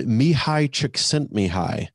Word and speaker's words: Mihai Csikszentmihalyi: but by the author Mihai Csikszentmihalyi.